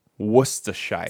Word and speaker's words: Worcestershire: It's spelled Worcester, but it's, you say it like they say in Boston. Worcester. Worcestershire.